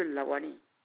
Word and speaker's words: Lawani: C'est monsieur Lawani. – La L-A L-A-W-A-N-I. – Ok, comme ça se prononce.